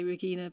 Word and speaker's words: Regina: Yeah, I think a Danish person would say Regina, but Regina is fine. – I'm sorry. – It's more natural for an English person to say Regina.